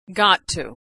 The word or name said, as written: gotto